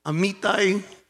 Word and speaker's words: Amitai: Bueno, tiene acento en la I, Amitai, algo así,